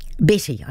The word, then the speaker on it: Bette: Sometimes it was Bet or B-E-T-T-E. We would call it Bet, but she was always Betty. Sorry, we would call it Betty.